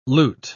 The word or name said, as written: lute